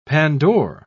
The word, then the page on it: pandore: British English